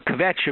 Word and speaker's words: kvetch: I have a kvetch about your segment on not kvetching. What did you expect? Kvetch is one syllable, not two. There is no vowel between the K and the V.